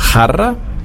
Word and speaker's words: jarra: Dann hatten wir das J, da hatte ich das Beispiel mit dem großen Bier vom Fass, jarra. Das J wird ausgesprochen wie ein CH, das vom auch.